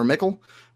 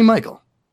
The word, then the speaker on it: Michel: You know, I figured it was like it looked like it was not pronounced Michael or Mickle, but yeah. I was going to go with it. I like Sonny Michel.